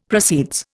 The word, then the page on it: proceeds: Indian